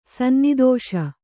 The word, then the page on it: ಸನ್ನಿದೋಷ: kannada